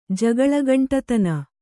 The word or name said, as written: ಜಗಳಗಂಟಿತನ